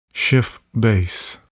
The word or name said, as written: Schiff base